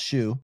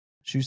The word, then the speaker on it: Xu: First of all, your name, X-U, is pronounced Shu. Shu Simon.